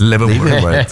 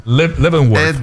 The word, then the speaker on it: Leavenworth: Leavenworth. – Qué bien lo pronuncias. – Leavenworth.